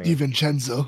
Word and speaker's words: DiVincenzo: DiVincenzo.